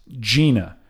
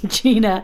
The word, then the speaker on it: Gina: That's G-I-N-A, and it's pronounced Gina. Well, your salesman, Dwight Schrute, kept pronouncing it Gina, like vagina.